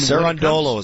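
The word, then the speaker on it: Cerúndolo: And all of a sudden, his opponent starts winning games, Sinner's hobbling around, and somehow, some way, as a 32-1 underdog, Cirundolu, I believe is how you say his name, beats Sinner. I mean, Cirundolu is how you say his name.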